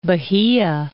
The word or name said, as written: Bahia